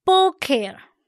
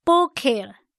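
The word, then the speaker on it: Boker (בֹּקֶר): 今天我们要看的字是早晨，读音 Boker Boker。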